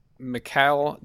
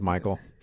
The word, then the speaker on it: Mikael: And this artist here is, his name is Mikhail Delta. – Good pronunciation. I would have gone with Michael. – I could be Michael, dude. – But no, I think Mikhail, that's probably right.